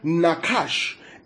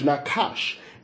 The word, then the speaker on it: nachash: Now, the word serpent is the Hebrew word nakash. N-A-C-H-A-S-H, Nakash. N-A-C-H-A-S-H, Nakash. Nakash, N-A-C-H-A-S-H.